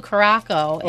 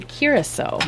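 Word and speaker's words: Curaçao: So we were saying Blue Caraco, and I was not in the room for that. – He very kindly let us know in a polite, very polite fashion, and sent us a video on how to say it. So it's Blue Curacao.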